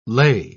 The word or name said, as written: lay